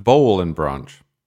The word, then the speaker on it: Boll & Branch: You know, I learned, despite having used these sheets for 2, 3, maybe 4 years now, that it's pronounced bowl and branch, and that I've been saying it wrong.